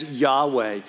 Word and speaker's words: Yahweh: And we know that name as in a Hebrew pronunciation as Yahweh.